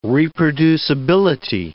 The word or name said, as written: reproducibility